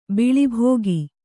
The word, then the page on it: ಬಿಳಿಬೋಗಿ: biḷi bōgi